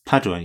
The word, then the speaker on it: Padua: Padua in Italy. – Padua, yeah.